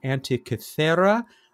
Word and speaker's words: Antikythera: As I said, in the Netherlands, she indeed said it's the Antikythera.